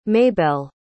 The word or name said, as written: maybelle